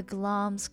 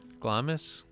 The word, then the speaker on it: Glamis: It is spelled like Glamis in my Ohio nasally accent. Glamis, but is pronounced Glom's.